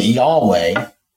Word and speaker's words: Yahweh: We pronounce it Yahweh,